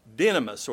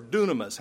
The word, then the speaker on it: dynamis: That word power is the Greek word dynamis or dunamis, however you pronounce it, whichever tradition you go with.